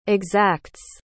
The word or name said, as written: exacts